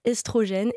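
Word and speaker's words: œstrogène: Donc le « e » dans l'eau, quand c'est suivi par une consonne, se prononce « é ». On devrait dire « estrogène »,« ésophage », etc.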